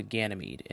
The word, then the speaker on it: Ganymede: And now on to Ganymede, and I did look up the pronunciation this time beforehand.